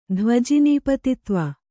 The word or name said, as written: ಧ್ವಜಿನೀಪತಿತ್ವ